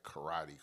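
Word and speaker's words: Corradi: And I think the last name is Karate. Not like karate. It's Karate. C-O-R-R-A-D-I. I guess that's how you would pronounce it.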